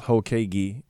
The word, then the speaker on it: Hokage: The name is pronounced Hokage, and it's an anime thing.